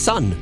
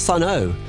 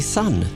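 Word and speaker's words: Sunn O))): Blimey, it's just Sun, not Sun O. You just say Sun.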